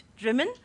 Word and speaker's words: Drymen: I was in Drimmen quite recently, a place that's spelt Drymen, as you know, but pronounced Drimmen, but lots of dry men about in Drimmen.